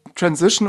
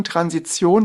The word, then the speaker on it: Transition: Der korrekte Begriff ist tatsächlich Transition oder auch deutsch ausgesprochen Transition.